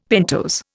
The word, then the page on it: pentose: Indian